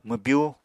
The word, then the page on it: mwbyú: Apatani